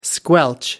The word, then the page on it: squelch: American English